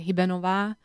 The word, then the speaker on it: Hibernova: So there are like many ways, I guess. So like the official way, I'm from Slovakia, right? So in Slovakia, I would say Hibernova, but many people here in America say Hibernova, which I'm totally fine with.